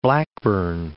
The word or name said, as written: Blackburn